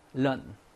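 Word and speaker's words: lợn: 베트남 말로 런, 런. 자, 어 발음이지, 런. 짧고 자체가 낮아, 런.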